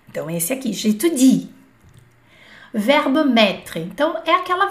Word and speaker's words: j'étudie: Por muito tempo, quando eu comecei a estudar, eu falava, eu estudo francês, j'étude. Getude, Getude. Não pode. É Getudi, porque tem esse izinho aqui antes do e.